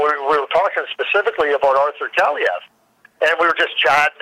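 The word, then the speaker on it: Kaliyev: Well, I mean, Kayalev is how you pronounce his name, and 51 goals, 51 assists.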